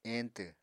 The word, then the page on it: eñtw: Apatani